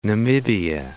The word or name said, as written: Namibia